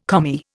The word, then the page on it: Commie: Indian